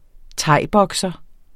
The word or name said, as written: thaibokser